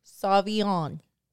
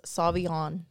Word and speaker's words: Sauvignon: Sauvignon. But it's not really spelled the way it's pronounced. Sauvignon. – Is it N-O-N? – Sauvignon.